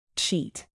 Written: cheat

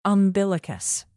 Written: umbilicus

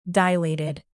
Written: dilated

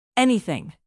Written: anything

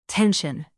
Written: tension

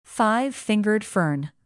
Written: five-fingered fern

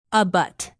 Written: abut

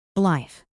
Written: blithe

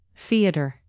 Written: theater

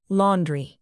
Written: laundry

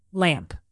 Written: lamp

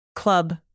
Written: club

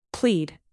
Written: plead

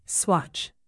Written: swatch

